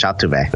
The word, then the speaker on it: Altuve: That's how you say it in Spanish, Altuve.